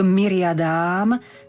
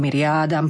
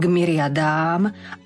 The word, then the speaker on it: myriadám: Potom máme v tom ďalšom odseku k vrchu Sion, opäť k mestu živého Boha, opäť k nebeskému Jeruzalému, k myriadám, tam je ten dlžeň na konci, nie k myriadám, alebo k myriádám, pozor, k myriadám, anielov, opäť mekko vyslovíme, ale tu už je spojenie neznelých spoluhlások.